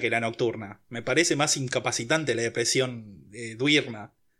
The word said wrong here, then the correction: diurna: Duirna, ¿cómo se dice? – Diurna.